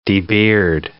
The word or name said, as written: debeard